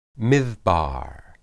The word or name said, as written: midbar